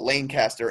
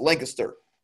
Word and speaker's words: Lancaster: And then there's Lancaster, which people like to call – because the E's and they're silent, and they like to call it Lancaster, and it drives me insane. It's Lancaster, not Lancaster. Like, it drives me – like, you live in this state, know how to pronounce it, please.